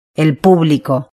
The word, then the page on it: el publico: Spain